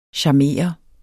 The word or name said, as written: charmere